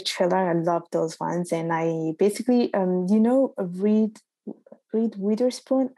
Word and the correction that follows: Reese Witherspoon: I know I'm not pronouncing her name properly. – Yeah, Reese Witherspoon.